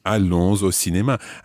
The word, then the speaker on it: allons: Allons au cinéma. And you can pronounce the S at the end of allons because it's followed by O.